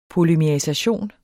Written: polymerisation

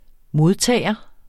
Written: modtager

